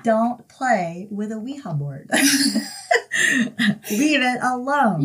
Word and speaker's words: Ouija: which, how do they pronounce it? Weeha. – Oh, did they? – Yeah, Weeha. Yeah.